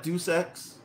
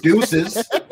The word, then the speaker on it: Deus Ex: How long I used to call that Deus Ex? – Deuces.